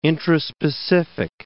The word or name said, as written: intraspecific